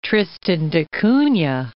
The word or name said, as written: Tristan da Cunha